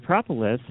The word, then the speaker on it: propolis: And I, you know, and I think from what I read, propolis has been, is that how you say it now, propolis? – Propolis, right.